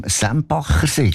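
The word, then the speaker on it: Sempachersee: Der vom Sembachersee, gell? Das habe ich richtig gesagt. Guten Abend. – Ja, am Sembachersee.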